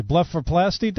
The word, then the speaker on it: blepharoplasty: and then I also have down here blepharoplasty. Blepharoplasty, doctor? I'm butchering that name. – It's blepharoplasty.